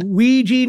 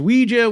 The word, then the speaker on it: Ouija: do, do, I have to work on Ouija now versus Ouija? Well, like what, what's the uh, what's the accepted uh, pronunciation? But I'm glad that they they accept both.